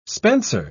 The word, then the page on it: spencer: British English